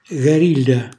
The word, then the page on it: garilla: Calabrese